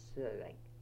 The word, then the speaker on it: Søreng: My girl name was Søreng. – Okay, could you spell Thomasley for me? – Yeah, T-O-M-S-L-I, Thomasley.